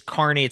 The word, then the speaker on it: Kearney: Is this like when I think Kearneys, I'm thinking like carnivals. I'm thinking of like a circus. – Yeah, it's Kearney. It's spelled K-E-A-R-N-E-Y. – Oh, okay. – Yeah, so it's really Kearney, but you know, anyway, there's these sandhill cranes that fly through every year.